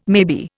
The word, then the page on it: maybe: Indian